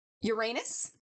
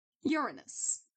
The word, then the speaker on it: Uranus: So he can also be, his name can also be pronounced Uranus. Pause for the giggle of the listeners. and us, apparently. Or Uranos. And this is when it's spelled with an O at the beginning.